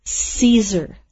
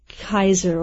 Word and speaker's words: Caesar: Okay, and that is, in English is pronounced Caesar, not、yeah. Kaiser or Keiser.